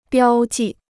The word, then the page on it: 标记: biāo jì